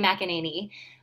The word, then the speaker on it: McEnany: I said her name right, right? – McEnany? Yeah, Abby McEnany. Yeah.